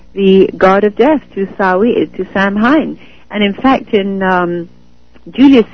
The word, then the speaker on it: Samhain: So this was the god that was celebrated by the druids, by the priests and the Celts, was Samhain, pronounced Samhain.